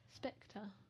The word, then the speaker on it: Spectre: Spectra. – Spectre. – Spectrap. – Spectre. – That's how I say it. – Well, you're saying it wrong.